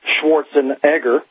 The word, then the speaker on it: Schwarzenegger: But I'm also seeing the encouragement of the girly man, which was first coined the phrase by Arnold Schwarzenegger, actually pronounced Schwarzenegger, but the Americans can't seem to pronounce the name correctly, so he gave up on that.